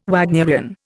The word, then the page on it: Wagnerian: Indian